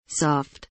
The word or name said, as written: soft